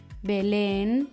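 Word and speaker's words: Belén: Erstens Aguda. Diese werden auf der letzten Silbe betont, zum Beispiel das Papier, el papel, der Lastwagen, el camión, Bethlehem, Belen. Die Wörter,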